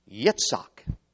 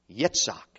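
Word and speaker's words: Yitzhak (יצחק): Let's give it the Hebrew pronunciation. Yitzhak. Yitzhak.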